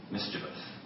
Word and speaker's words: mischievous: Am I pronouncing that right, or is it mischievous? Mischievous. Okay, I think my mother used to say mischievous. That's a Jamaican way of talking.